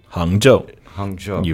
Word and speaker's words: Hangzhou: And Hangzhou. – Hangzhou.